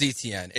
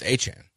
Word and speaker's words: Etienne: Like, his name is Etienne. It's been Etienne. Since he was at Clemson, he signs with the Saints and he goes, that's not how you say my name. It's Achan.